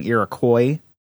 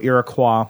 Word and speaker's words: Iroquois: First, he wrote in and told us, "It's always funny to hear Americans pronounce Iroquois. I think we were saying Iroquois. Yeah. So Iroquois.